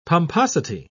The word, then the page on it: pomposity: British English